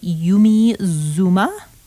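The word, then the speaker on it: Yumi Zouma: In Camera by Yumi Zuma.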